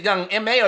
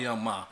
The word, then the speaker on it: Young M.A: Or do you want to start on this this young, is it Young M.A. or Young Ma? I call him Young Ma. That's what it's spelled. – Young M.A., yeah, my nigga. – I'm calling Young Ma. – Nah, it's Young M.A.